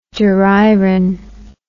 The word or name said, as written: duriron